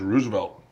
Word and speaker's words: RSVLT: No, this is Roosevelt. – Ah. – But although it's spelled without the vowels, so it's RSVLT.